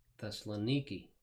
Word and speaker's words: Thessaloniki: And you may not be able to see it on the map here, but notice the modern spelling is with a K-I at the end rather than a C-A, and they pronounce it Thessaloniki.